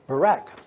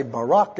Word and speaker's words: barak: The third word is barak. I was going to say Barak, and I was looking that word up, and it pronounced it with a B-E, Barak.